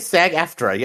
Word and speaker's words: SAG-AFTRA: Did I say that right? SAG-AFTRA, yeah.